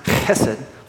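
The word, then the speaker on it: chesed (חסד): The Hebrew word for kindness there is hesed. It's actually pronounced chesed, chesed.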